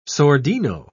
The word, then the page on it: sordino: British English